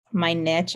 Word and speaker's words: niche: That's kind of how do you pronounce it? You're a writing person. Is it niche? What is it? – I would say it's my niche, I guess. – Niche.